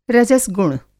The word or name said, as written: रजस गुण